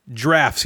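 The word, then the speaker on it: draughts: Drafts.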